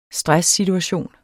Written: stresssituation

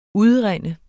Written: UDRUNDEN

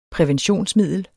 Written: præventionsmiddel

